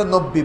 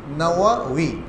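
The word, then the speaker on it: নাওয়াউই (النووي): তো বাংলায় উচ্চারণে ব ব দেওয়ার কারণে আমরা নব্বী বলি আসলে নব্বী নয় নাওয়াউই দুটো ওয়াও দুটো ওয়াও ইমাম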